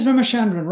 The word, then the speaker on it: Ramachandran: And so then how did you meet, now, am I going to say this right, Dr. V.S. Ramachandran? Is that right? – Yes, Ramachandran, Rama.